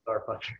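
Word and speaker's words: STRFKR: It's spelled weird. It's like, but it's Starfucker.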